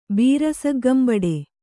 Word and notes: ಬೀರಸಗ್ಗಂಬಡೆ: kannada

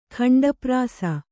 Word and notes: ಖಂಡಪ್ರಾಸ: khaṇḍa prāsa

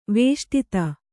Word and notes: ವೇಷ್ಟಿತ: vēṣṭita

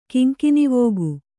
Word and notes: ಕೆಂಗಣ್ಣವಕ್ಕಿ: keŋgaṇṇavakki

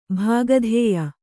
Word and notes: ಭಾಗಧೇಯ: bhāgadhēya; Kannada

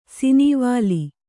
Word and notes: ಸಿನೀವಾಲಿ: sinīvāli

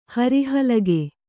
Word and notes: ಹರಿಹಲಗೆ: Kannada

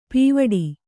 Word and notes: ಪೀವಡಿ: pīvaḍi

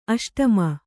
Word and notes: ಅಷ್ಟಮಿ: aṣṭami

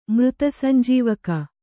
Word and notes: ಮೃತಸಂಜೀವಕ: Kannada

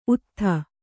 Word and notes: ಉತ್ತುತೆ: Kannada

